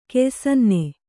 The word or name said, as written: ಕೆಯ್ಸನ್ನೆ